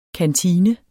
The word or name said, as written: kantine